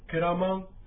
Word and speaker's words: ケラマン: で、このアイヌ語に対して私は分かりますという答え、これは猿川流域ですから、ケラマンというふうに発音いたします。これはもともとはクエラマンということが、猿川流域ではくっつきまして、ケラマンというふうに発音します。